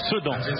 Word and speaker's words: Sedan: Sedan. Pour une fois que je peux vous reprendre sur une prononciation.